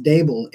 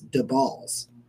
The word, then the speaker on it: Daboll: So he says that in case you're curious, it's not pronounced Dable, it's DeBalls. That's what he told me. It's the balls.